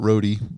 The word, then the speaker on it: Rode: I just want to say thank you to the good folks at Rode Microphones. I think I'm saying that wrong. I call it Rode, but that's because I'm an idiot. It's actually Rode, R-O-D-E.